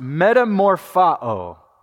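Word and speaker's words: metamorphao (μεταμορφόω): This word in the original language, it's pronounced metamorphao.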